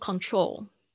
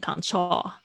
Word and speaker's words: control: 注意一下你的 L 的声音也是一样，哦哦，舌尖顶在上排牙齿的后面，哦，发出哦的声音，remote control。所以你听一下它的发音。 – remote control, remote control。 OK，就是这样子的感觉，呃，就是大家不要把那 L 的声音变成卷舌音，或者是不发音，会不会变成 control 啊、就错了。